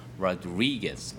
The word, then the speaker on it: Rodriguez: 예, 지금 로드리게스라고 우리가 발음을 많이 하는데 당연히 미국인들은 로드리게스라고 발음을 안 하겠죠. 그 강세도 Rodriguez 이렇게 있으니까 주의하시기 바랍니다.